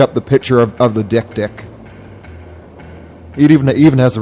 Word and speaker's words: dik-dik: and apparently there's an animal called the Dick Dick, which is spelled D-I-K-D-I-K, and it's this tiny antelope sort of creature, and it marks its territory by crying, and it looks really sad.